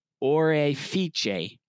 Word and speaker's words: Orefice: But I had to look up how to say the last name in Italian correctly. It's Guido Orefice.